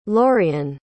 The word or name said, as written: lorian